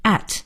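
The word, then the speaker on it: @: 那么这个 A 圈应该怎么读呢？有的同学读圈 A 花 A，这都不对。在英文里呢，应该是读成 at，就是我们新学的这个 at 这个字。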